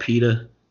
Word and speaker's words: PETA: I think it's called PETA, spelled like P E T A and shit. Not like Peter Bread.